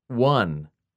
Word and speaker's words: won: Win, won, won. Win rhymes with in and lin. Won rhymes with sun and fun.